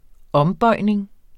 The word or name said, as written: ombøjning